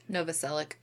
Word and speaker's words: Novoselic: Novoselic.